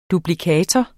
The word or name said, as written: duplikator